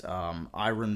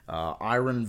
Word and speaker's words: Irion: You can check me out at Iron Voices. That's spelled IRION, which is my last name. Iron Voices.